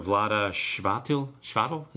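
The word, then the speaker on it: Vlaada Chvátil: That's the company with designer Vlada Shvatil. Is that, am I pronouncing that right? – Sounds right.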